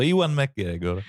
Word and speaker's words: Ewan McGregor: Infatti figurano Ewan McGregor. Mi piace tantissimo, ma non so pronunciargli il nome. – L'hai detto giusto, Ewan McGregor.